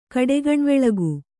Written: ಕಡೆಗಣ್ವೆಳಗು

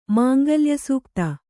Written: ಮಾಂಗಲ್ಯಸೂಕ್ತ

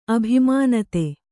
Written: ಅಭಿಮಾನತೆ

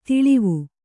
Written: ತಳಿವು

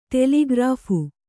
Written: ಟೆಲಿಗ್ರಾಫ್

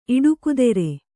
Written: ಇಡುಕುದೆರೆ

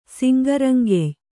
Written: ಸಿಂಗರಂಗೆಯ್

